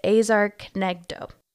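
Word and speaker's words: ezer kenegdo: And so the Hebrew phrase for suitable helper is azar knegdo. That is my Gentile interpretation of this Hebrew phrase. So forgive my mispronunciation. – I think that was pretty accurate.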